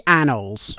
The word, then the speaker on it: annals: That's a really good intro, Duncan, but I did notice that you pronounced it as an annual, like a collection of Beano magazines. It's the annals.